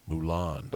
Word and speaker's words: Moulin: I think it's like Moulin Rouge. It's probably all right, we'll call it that.